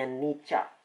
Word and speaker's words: Nietzsche: Kali ini dikaitkan dengan ide Nietzsche, seorang filsuf dari Jerman, dibacanya Nietzsche, konsep eternal recurrence atau pengulangan abadi.